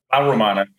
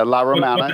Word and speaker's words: La Romana: Romana. Did I say that right? – La Romana, yeah. – Yeah, La Romana. – Put the L before it there.